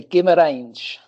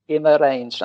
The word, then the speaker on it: Guimarães: How do you pronounce this place? – It's not so easy. Guimarães. – Guimarães. – Guimarães, that's right, yeah.